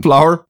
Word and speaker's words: flour: We we got a bit of uh, uh, help with uh, floor flour yeah. the, the, other day. Um, flour. I, I said floor again.